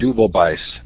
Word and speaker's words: Deubelbeiss: It is the last day of July 2012, and this is David Deubelbeiss.